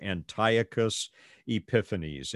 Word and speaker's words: Antiochus Epiphanes: It's really closer to Antiochus Epiphanes. Antiochus Epiphanes. He was the son of King Antiochus, and he had been brought up in Rome as a hostage. Antiochus Epiphanes began to rule in the year 137, according to the calendar of the Greek kingdom.